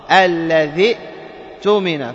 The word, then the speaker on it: الذي اؤتمن: الآن اجتمعت هاتان الهمزتان مع الكلمة التي قبلها، والكلمة التي قبلها فيها ياء ساكنة، فيها ياء ساكنة، التقى ساكنان، فإذا التقى الساكنان فماذا نفعل؟ نحذف الساكن، نحذف هذا الساكن، فنحذف الياء ونحذف كذلك همزة الوصل، لأننا نقول الذي تؤمن، واضح؟ الذي تؤمن، فننطق بهمزة القطع، ننطق بهمزة القطع، معي؟